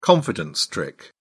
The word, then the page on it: confidence trick: British English